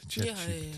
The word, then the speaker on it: ChatGPT: ChatGPT.